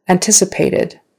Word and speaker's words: anticipated: Anticipated. Anticipated. Again, the accent is on the second syllable. Anticipated.